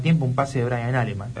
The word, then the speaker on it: Alemán: Alemán, lo dijo el propio jugador. – En la segunda. – Alemán, sin tilde. – Acento en la primera.